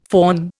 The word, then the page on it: faun: Indian